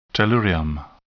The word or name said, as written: tellurium